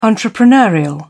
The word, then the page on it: entrepreneurial: British English